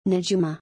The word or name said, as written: Najuma